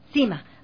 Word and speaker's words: SYMA: Cima Ecuador, se escribe la S-Y-M-A.